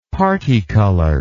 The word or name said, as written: particolored